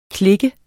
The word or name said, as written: klik